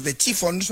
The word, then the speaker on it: The Chiffons: Años antes había triunfado otra creación, He's So Fine, compuesta por Ronald Mack e interpretada por The Chiffons, no sé si lo he dicho bien.